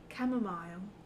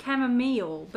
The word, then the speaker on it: chamomile: You said it in like a British way. – Chamomile. – Yeah, mile. – I would say chamomile, but that's when, you know, my uncultured American self.